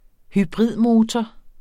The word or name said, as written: hybridmotor